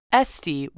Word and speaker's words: Esty: When Jeremy was 4 years old, his sister Esty was born, spelled S-apostrophe-T-E, like the French abbreviation for the word saint, but pronounced Esty, to Diane and the new man in her life, who I believe's name is John Bright.